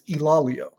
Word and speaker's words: Ilaleo: and he said it's Ilaleo. So he says people make it multiple syllables, not technically correct.